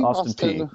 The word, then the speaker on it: Austin Peay: How do you pronounce that? Is it Austin P? – Austin Peay, yep.